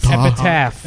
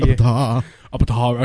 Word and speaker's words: Epitaph: So I got Epitaph. Epitaph. Epitaph, Steve. He can't pronounce the P. – Abatah Records.